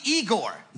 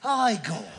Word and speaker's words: Igor: Must be Igor. – No, it's pronounced Igor.